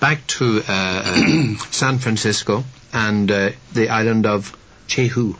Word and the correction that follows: Jeju: Is that how it's pronounced? – Jeju.